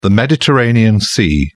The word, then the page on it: mediterranean: British English